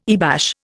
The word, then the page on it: earbash: Indian